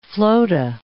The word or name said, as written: flota